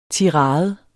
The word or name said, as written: tirade